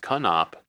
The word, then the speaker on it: Knopp: My last name is actually pronounced Knopp.